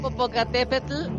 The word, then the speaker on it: Popocatépetl: Popopacate, Venga, lo he dicho una vez. Live, hay livecamp, Popocatépetl y hay Livecam igual porque a,